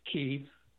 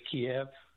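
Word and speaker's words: Kyiv: please, don't say Kiev, say Kiev. – Kiev is the Russian pronunciation. Kiev is the Ukrainian pronunciation.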